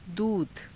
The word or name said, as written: duudha